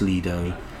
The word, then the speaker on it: Lido: It's pronounced Lido. And so I say Lido. Nath picks me up on it as well. But then, to be fair to Nathan, he also said, yeah, he's been there. I haven't even got the excuse to be. I've never even been to it. But Lido is the Italian pronunciation, and that's where the word comes from.